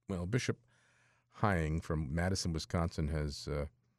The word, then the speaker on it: Hying: Uh, Bishop Donald Hying, I believe that's how you pronounce it, Hying, H Y I N G, of Madison, Wisconsin, uh, has spoken out um, against the destruction of statues, specifically religious statues.